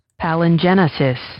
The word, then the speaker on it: palingenesis: Palingenious is our word of the day. Palingenious. Let's make sure I'm pronouncing that correct. And let's get a pronouncer. – Palingenesis. – Palingenesis. – Palingenesis. – Yeah, I was going to say, it looks like Genesis to me.